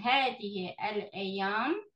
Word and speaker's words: هذه الأيام: هذه سوري هل هذه الأيام So if you don't pause between these two words, which usually you don't pause between هذا and the next word, the alif of L is not pronounced. You go straight from هذه to the lam.